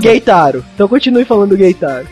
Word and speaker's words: Keitaro: Mas enfim, o Keitaro, que é o personagem principal... – Não, calma aí. Ó, vamos lá que a gente gosta de uma pronúncia certa aqui no United Cast. É Geitaro.